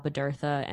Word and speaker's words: Bodurtha: You can follow me, Val Bodurtha, NYC. My last name is spelled Bodurtha. It is spelled like it sounds.